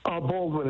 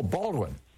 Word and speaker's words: Baldwin: Balmain, New York. Am I saying that right? – Baldwin is fine. – Oh, Baldwin. Okay. – Like Alec.